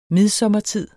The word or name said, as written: midsommertid